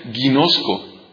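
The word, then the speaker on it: ginosko (γινώσκω): La palabra saber en griego es una palabra que se pronuncia ginosko.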